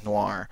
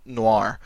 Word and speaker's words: noir: Noir, of course, is the French word for black, only they pronounce it with a different sounding R that I'm not going to try and emulate.